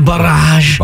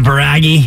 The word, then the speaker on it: barrage: Is that the French pronunciation, kind of? Barrage? – Yeah, barrage. – Barrage. – As opposed to baragi.